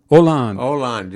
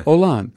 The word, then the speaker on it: Hollande: And they were talking about applying this to this guy, Hull. Is that how you pronounce it? Who just stepped out, a prime minister, president of France? – Hollande. Yeah. – Oh, wrong guy. Yes, well, yeah. Hollande.